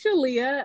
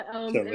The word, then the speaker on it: Shalia: Yes, I mean, you put an extra emphasis on that Sha, but it's Shalia. Shalia. And what I like to tell people is that it rhymes with Aaliyah, and that kind of helps to cement with the word association.